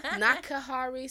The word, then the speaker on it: Nakihari: Samara Nakahari Salam. Nakahari is N-A-K-I-H-A-R-I, not N-A-K-A, like some of y'all be trying to spell it.